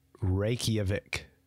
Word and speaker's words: Reykjavik: And this is like a direct flight from Toronto to Reykjavik. I pronounced that pretty good. – Isn't that? – I did that pretty good. – Yeah. Isn't that Reykjavik.